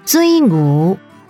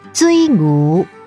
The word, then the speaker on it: 水牛: 水牛台语念作追牛追牛，客语念作水牛水牛。 – 水牛台语念作追牛追牛。